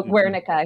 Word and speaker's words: Guernica: but Pablo Picasso's Guernica, I believe that's the pronunciation of it. – Guernica. – Oh, Guernica, excuse me.